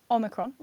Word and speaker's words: Omicron: So because everybody has been mispronouncing the word Omicron, and that's how it should be pronounced, Omicron, with a short O at the beginning. Some people are saying it Omicron,